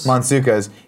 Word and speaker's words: Mantzoukas: Mantzoukas.